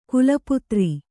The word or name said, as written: ಖಲಪಾತ್ರ